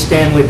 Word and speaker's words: Stanley: My name is Stanley Bastian. That's S-T-A-N-L-E-Y. Last name B-A-S-T-I-A-N.